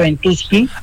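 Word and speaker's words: Ventusky: La página se llama Ventuski con B corta. Ventuski lleva que de kilo, como para así se pronuncia Ventuski.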